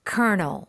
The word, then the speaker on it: colonel: Okay, colonel. Colonel. Now, what you hear is not what you see.